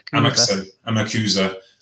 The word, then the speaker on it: Amakusa: So Hunter Miyakawa, Amax, I never pronounced his name properly. Amakusa, and Alejandro.